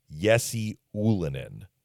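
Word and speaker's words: Jesse Ylönen: and I think it's pronounced Jesse Ullinen. His dad was Juha Jalonen when he played in the NHL. So I don't know if Jalonen was the wrong pronunciation, and now it's Ullinen, or if the son just said, I want to be known as Ullinen. That's how they're pronouncing it.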